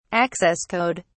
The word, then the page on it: access code: British English